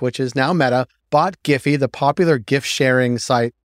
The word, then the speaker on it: Giphy: For our second story, UK regulators are demanding Facebook parent company Meta to sell Giphy, and we are saying Giphy, and that is the correct way to pronounce it.